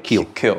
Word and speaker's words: Kiel: Kiel.